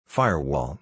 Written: Firewall

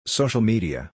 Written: Social Media

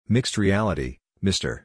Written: Mixed Reality (MR)

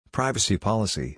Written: Privacy Policy

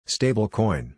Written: Stablecoin

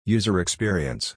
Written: User Experience

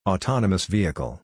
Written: Autonomous Vehicle

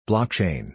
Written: Blockchain

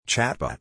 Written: Chatbot

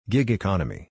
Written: Gig Economy

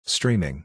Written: Streaming

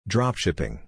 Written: Dropshipping

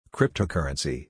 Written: Cryptocurrency

